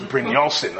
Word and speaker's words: Brynjolfsson: He has a TED talk and I listened to a bunch of his talks and I always heard them pronounce his name. So it's Brynjolfsson. I almost know how to spell it because I've seen his name so much now.